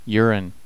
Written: urine

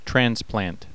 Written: transplant

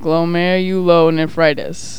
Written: glomerulonephritis

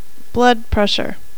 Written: blood pressure

